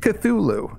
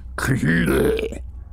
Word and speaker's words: Cthulhu: Then he said, take Cthulhu. And this is Georg's comment. I couldn't help starting as I heard the word pronounced for the first time in my life. The harsh, dark, monosyllabic growl